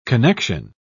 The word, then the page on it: connexion: British English